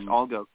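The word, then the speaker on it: Allgäu: The Erfurt Indigos, pardon my pronunciation while I butcher this, was it Algo Comets? – Yes, Algo Comets.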